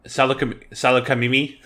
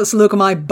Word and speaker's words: Salukami: Everybody calls it Salukami, but him. He calls it Salukami. – He puts the accent on the wrong syllable.